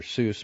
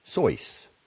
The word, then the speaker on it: Seuss: Dr. Seuss's mother, also the daughter of German immigrants, was Henrietta Seuss, and when he appropriated the name for his books, Dr. Seuss pronounced it in the German manner, Seuss, until he realized that Americans naturally read the name as Seuss,